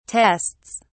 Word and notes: tests: British English

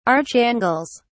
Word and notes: archangels: British English

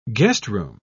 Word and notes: guestroom: British English

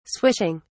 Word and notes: swishing: British English

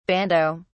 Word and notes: bandeaux: British English

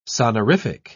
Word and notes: sonorific: British English

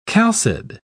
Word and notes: chalcid: British English

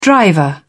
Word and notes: driver: British English

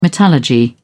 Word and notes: metallurgy: British English

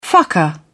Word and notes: fucker: British English

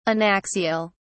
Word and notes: anaxial: British English

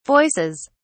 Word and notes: voices: British English

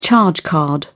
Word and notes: charge card: British English

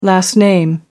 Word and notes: last name: American English

